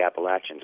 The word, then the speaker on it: Appalachians: But he was at home on the other side of the Appalachians.